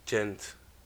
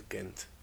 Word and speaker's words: Ghent: And in 1978, they were able to get the hold of yeast and a recipe from Augustiner monks in Ghent. Or Ghent. I think it's Ghent. I think I pronounced it wrong. I think it's Ghent.